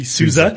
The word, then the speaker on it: SUSE: We discussed that earlier with regards to the name, how you pronounce it, and it is definitely SUSE. So that was a brilliant pronunciation. I know a lot of guys actually battle with that.